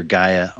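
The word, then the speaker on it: Gai (γῆ): You know, we hear Gaia a lot these days. It's G1093, pronounced Gai, G-E.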